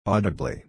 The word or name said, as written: audibly